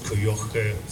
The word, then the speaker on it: Kujocha: Thank you very much, Helene Heiß, and I'm very glad you pronounced Kujocha the right way.